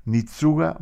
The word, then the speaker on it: Nitsuga: Él se llamaba, como decía antes, Agustín Barrios, pero se presentó muchas veces en los conciertos como Nitsuga Mangoré, donde Nitsuga en realidad es Agustín, su nombre. – Pues suena japonés, ¿eh? – Pronunciado al revés.